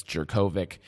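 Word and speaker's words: Jurkovec: And I never say his name right. I guess it's Jerkovic.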